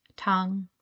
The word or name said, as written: Tang